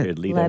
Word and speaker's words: Jared Leto: Jared Leto, is that how you say it? – Jared Leto. – Leto, I'm sorry.